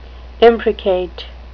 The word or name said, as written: imprecate